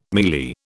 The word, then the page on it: mielie: British English